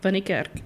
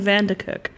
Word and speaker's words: van Niekerk: Well, you did say Dane. In fact, you said Dane van der Kerk.